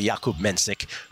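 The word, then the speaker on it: Jakub Menšík: That's how it's spelled. It's really Jakub Mensick, who we, as Will said, we made a ton of money on when I was on vacation.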